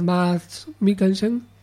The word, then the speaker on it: Mads Mikkelsen: Película interpretada por unos actores muy, muy interesantes, desde su protagonista, que es Mads Mikkelsen, espero haberlo pronunciado bien, sí, correcto.